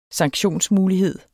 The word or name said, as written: sanktionsmulighed